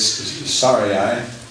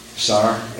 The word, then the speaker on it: Sarai: Now Sarah, we'll call her Sarah, it's Sarai, Sarai in my pronunciation here in the Bible.